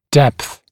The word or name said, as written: depth